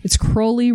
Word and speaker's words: Crowley: It's Crowley rhymes with holy is how he would pronounce it.